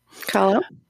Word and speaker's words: Kahlo: Kahlo.